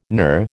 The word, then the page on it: NUR: American English